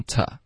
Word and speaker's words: to: She meant nothing，后面 to 呢，我们就弱读成 ta。